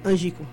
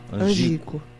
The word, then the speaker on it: Ângico: É isso mesmo a pronúncia? – É, Ângico. – Ângico. E da aldeia indígena Coelho Aticum Jurema.